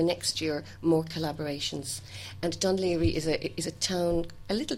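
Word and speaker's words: Dún Laoghaire: Dunleary, yes.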